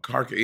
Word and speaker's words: Kharkov: Is it Kharkov? – Oh, Kharkov.